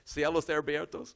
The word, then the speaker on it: Cielos Abiertos: We have, for example, up in Elkhart, a brand new church called Cielos Abiertos. Some of you know what that means. Is that open heaven? Did I say that right, Joe? Got it pretty close? All right. Cielos Abiertos.